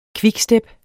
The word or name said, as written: quickstep